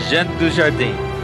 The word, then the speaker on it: Jean Dujardin: Yeah, Jean Dujardin.